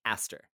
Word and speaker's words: Eastre: So just to kind of dig in a little bit here, the Old English name for Ostara is spelled E-A-S-T-R-E, and that is pronounced Aster.